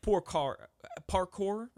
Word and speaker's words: parkour: And it's like parkour, right?